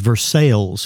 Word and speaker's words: Versailles: So what would be Versailles in France is Versailles here, but the narrator does not know this.